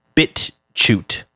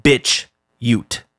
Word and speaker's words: BitChute: And I believe it's pronounced BitChute, not BitChute. I don't think they're talking about a woman with a bad attitude who's from the Ute tribe.